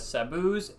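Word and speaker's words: Sabos: I've always pronounced it as Sabu's, S-A-B-O-S. People have pronounced it Sabo's.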